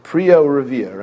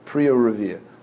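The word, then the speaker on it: Priya Revia (פריה ורביה): Ha'ish mitzuva al piri verivya v'lo isha. Right, what's the correct pronunciation of piri verivya? Just, what? Right? Priya Revia, right? Priya Revia.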